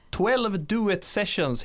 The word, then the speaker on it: 12 Duet Sessions: Ja, 12 Duet Sessions heter projektet, va? Uttalar jag det rätt? – Ja, men det uttalar du helt rätt.